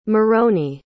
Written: moroni

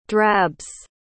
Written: drabs

